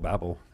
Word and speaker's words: Babel: And God, I mean, in the past, the Tower of Babel or Babel, we pronounce it Babel in England.